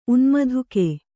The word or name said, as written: ಉನ್ಮಧುಕೆ